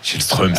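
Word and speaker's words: Källström: Shellström, c'était fou ça. Non mais Shellström c'était la bonne prononciation dans la langue du pays, mais personne comprenait de qui on parlait.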